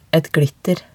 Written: et glitter

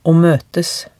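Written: å møtes

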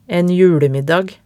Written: en julemiddag

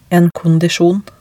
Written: en kondisjon

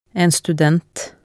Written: en student

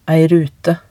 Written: ei rute

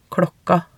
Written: klokka

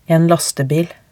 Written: en lastebil

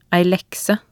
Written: ei lekse

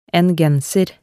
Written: en genser

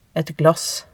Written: et glass

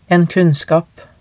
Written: en kunnskap

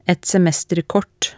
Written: et semesterkort

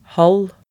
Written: halv

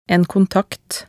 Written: en kontakt

